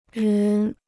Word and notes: 人: rén

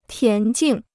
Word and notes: 田径: tián jìng

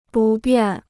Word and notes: 不便: bù biàn

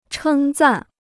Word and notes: 称赞: chēng zàn

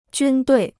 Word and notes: 军队: jūn duì

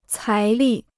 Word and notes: 财力: cái lì

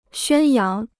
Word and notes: 宣扬: xuān yáng

